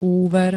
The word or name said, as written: úver